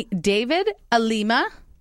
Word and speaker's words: David Alima: I have with me David Alima.